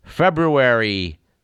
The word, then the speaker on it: February: Our date in question is February 6th, and it is a tradition on this program to note on our first February program that the month in question has two R's, and they are both pronounced.